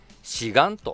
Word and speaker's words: 志願: うん、この志という字は止めるという辺に、カタカナの日のようなね、形の字を書いて志願と読みます。